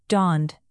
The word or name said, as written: donned